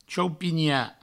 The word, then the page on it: chaupinhar: chaupigna; provençal